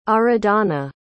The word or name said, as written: Aradhana